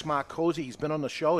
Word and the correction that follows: Micozzi: It's pronounced Micozzi. – That way, if anyone wants to Google him, it's M I, not M A R. It's Micozzi.